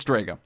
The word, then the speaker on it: Drago: Or Drago? Drago? I just watched the show. You would think I would pronounce his name right. It's Drago. We saw the return of Drago.